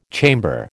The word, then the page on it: chamber: American English